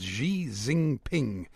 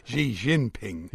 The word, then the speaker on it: Xi Jinping: President Xi Jinping, no, that's not how you pronounce it, Xi Jinping yeah.